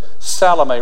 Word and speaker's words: Salome: Some people would pronounce that Salome or something else, but Salome.